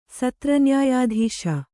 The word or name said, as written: ಸತ್ರನ್ಯಾಯಾಧೀಶ